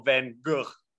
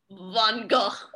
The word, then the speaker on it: Van Gogh: Do you have the correct pronunciation of Van Gogh? – Yeah, yes, I do.